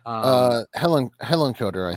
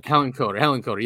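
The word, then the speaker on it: Hillenkoetter: So basically what Richard's saying here is that there was such a campaign against Kehoe and Hillencotter, is how you say his name? – Hillencotter, I think. – Hillencotter, yes.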